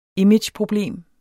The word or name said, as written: imageproblem